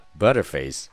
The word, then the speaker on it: butterface: 所以，如果以后你想说某个女生是背影杀手的话，你就可以把 but her face 这三个词给快速的读出来，就读成了 butterface。